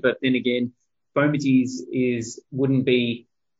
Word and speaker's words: fomites: Yeah, I'm still getting my head around fomites. – That's right. Well, we were both going to say fomites because it's spelt FOMITS. Sorry, ITES.